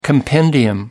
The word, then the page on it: compendium: British English